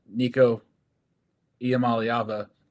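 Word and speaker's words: Nico Iamaleava: You know, their pass defense was, you know, able to lock down Nico Iamaliava.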